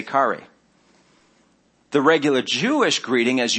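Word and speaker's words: kari (χαῖρε): The regular Greek greeting would have been probably how we pronounce kari, which is like chair with an E at the end of it.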